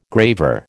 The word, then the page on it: graver: American English